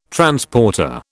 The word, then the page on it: Transporter: British English